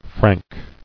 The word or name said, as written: Franking